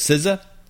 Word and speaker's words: SZA: And then another one that I guess that's the only one I know how to pronounce because SZA.